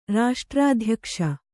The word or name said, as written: ರಾಷ್ಟ್ರಾಧ್ಯಕ್ಷ